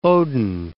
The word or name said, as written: Odin